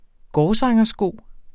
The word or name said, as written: gårdsangersko